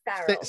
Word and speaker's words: Farrell: Yes, Farrell.